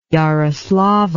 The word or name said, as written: yaroslavl